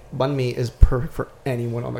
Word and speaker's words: banh mi: So, today, you've researched Banh Mi.